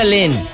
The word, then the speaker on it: Len: Según yo se pronuncia Len.